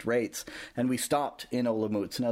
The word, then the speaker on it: Olomouc: Olomouc. – Olomouc.